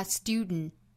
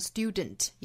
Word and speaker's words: student: 出来，很多单词里都包含这种组合，比如说像花园 garden 可以发成 garden，比如说像英国 Britain 这个词哈，那再比如说像学生这个词就可以发成 student，在后面的这个音节用鼻子去呛这么一下，发 student。当然我们也可以把它发成 student，也是对的。